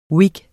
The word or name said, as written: whig